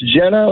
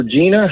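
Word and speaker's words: Genna: It's Jenna or Gina, however you want to say it. It's spelled with a G, G-E-N-N-A.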